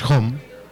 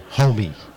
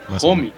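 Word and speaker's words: Homme: y no se pronuncia Home su apellido, se, pronuncia como Homie, algo así. – homie. – Ajá, como, como si fueras cholo, pero bien pronunciado.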